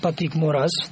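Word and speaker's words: Patrick Moraz: Это швейцарский музыкант Патрик Мораз, так, насколько я понимаю, надо правильно его произносить, который играл на альбоме ЕС 1974 года «Релейер».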